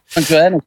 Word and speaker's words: Alan Johannes: Die uh, Alan Johannes, hoe heet die man? Zeg ik dat goed? – Ja, Alan Johannes.